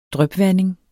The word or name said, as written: drypvanding